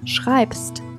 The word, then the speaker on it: schreibst: 这里发音要注意一下，你写是 du schreibst，词尾 b s t 三个辅音都是清辅音，所以是 schreibst。